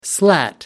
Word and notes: slat: American English

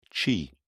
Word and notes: qi: British English

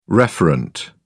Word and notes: referent: British English

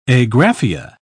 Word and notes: agraphia: British English

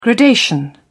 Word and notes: gradation: British English

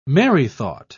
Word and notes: merrythought: British English

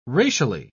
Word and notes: racially: British English

